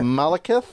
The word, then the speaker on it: Malekith: Malekith.